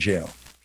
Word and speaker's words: gaol: It was G-A-O-L, Gaol, but it's pronounced jail. That's where we get J-A-I-L.